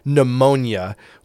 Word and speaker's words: pneumonia: Pneumonia, we see that word, and if you see it spelled correctly, you know, starts with a P and the P just disappears. All we pronounce is the N in that.